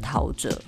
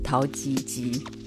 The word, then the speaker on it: 陶喆: 那里面呢，人物的轮廓被模糊了，在 David 跟 Tao 中间有一个巨大的惊叹号，然后底下写陶喆。这个名字刚出来的时候，很多人都没有办法把他的名字念对，所以大家都念成陶吉吉。